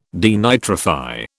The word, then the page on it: denitrify: British English